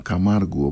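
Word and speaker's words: Camargo: Καμάργκο Καμάργκου, όπως προφέρεται στα βραζιλιάνικα, που είναι στην πραγματικότητα το όνομα το πατρικό της μητέρας του.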